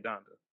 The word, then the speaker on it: Donda: Is it pronounced Donda or Donda? – It's his mom. Yeah, Donda.